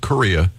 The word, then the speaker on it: Correia: It's Larry Correia, uh, C O R R E I A, but it's pronounced Korea,